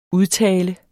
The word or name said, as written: UDTALENS